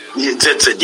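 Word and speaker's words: tzitzit: Lastly, many Torah observant believers wear, is it pronounced, it's T-Z-I-T. Is it Zitzit? – Zitzit, yes. That's probably the closest.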